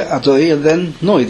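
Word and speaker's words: Adoy and then Noi (אדני): Milra means we say Adoy and then Noi. The stress is on the Noi. Yeah, stress on the Noi. Some people say it Milel. Adoy and then Noi. They stress the Adoy. But that's not correct.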